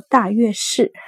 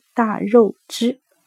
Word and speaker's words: 大月氏: 哦，我这里顺便更正一下，前面读成了大月氏，是我的女儿发现我读错了，应该是读大肉汁。大肉汁又称为塞族，并以塞族所居之地为世家，但是大肉汁之入印，有史可考的乃是贵霜王朝。